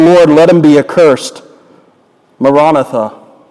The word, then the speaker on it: Maranatha: There's a little Aramaic word, Maranatha. Some pronounce it Maranatha.